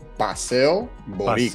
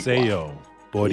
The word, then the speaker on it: Paseo Boricua: It's pronounced Paseo Boricua. – Paseo Boricua.